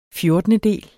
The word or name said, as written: fjortendedel